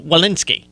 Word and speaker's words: Walensky: Uh, Dr. Rochelle Lewin- Walensky. That I pronounced it, Dr. Rochelle Walensky.